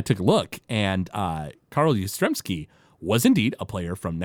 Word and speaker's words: Yastrzemski: But Milhouse comes in, and he wants the Carl, how do you pronounce that name? – Carl Yastrzemski? – Yastrzemski. That's how it is.